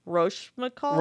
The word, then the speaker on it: Rochefoucauld: Rochefoucauld.